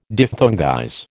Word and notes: diphthongize: American English

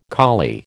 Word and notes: collie: American English

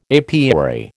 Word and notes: apiary: American English

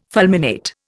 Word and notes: fulminate: Indian

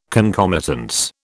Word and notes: concomitance: British English

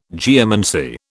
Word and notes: geomancy: British English

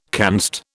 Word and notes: canst: British English

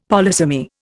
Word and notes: polysemy: Indian

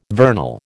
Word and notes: vernal: American English

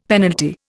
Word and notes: penalty: Indian

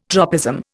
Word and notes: tropism: Indian

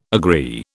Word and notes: agree: British English

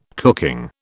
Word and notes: cooking: British English

